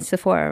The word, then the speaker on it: Sephora: Sephora, they're, sorry if any of you guys work at Sephora, but every single time I walk into, am I saying it right? Sephora, right? – Yeah.